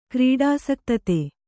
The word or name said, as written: ಕ್ರೀಡಾಸಕ್ತತೆ